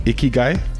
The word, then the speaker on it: Ikigai: Ikigai? – Right.